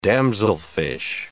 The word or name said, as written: damselfish